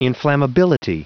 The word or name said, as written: inflammability